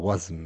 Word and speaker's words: WASM: Man sagt aber WASM, ne? WASM. Ja, ich bin auch noch nicht so warm damit. Boah, da bin ich auch wieder. Muss man erstmal wieder reinschauen. Wasm jenseits des Browsers sagt mir gar nichts.